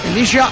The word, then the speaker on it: Elisha: ¿Está bien dicho Elisha? – Elisha Owusu. – Ahí sí lo dije bien.